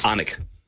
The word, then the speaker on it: Alnwick: The first is A-L-N-W-I-C-K. – Yeah, okay. Anik. – I'll go with Alec. – Anik. – Go again. – Anik. – Righto, so the L is silent.